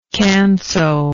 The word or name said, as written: canso